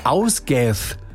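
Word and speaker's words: Ásgeir: Wäre der Ausgath, so spricht man ihn aus, ein isländischer Sänger.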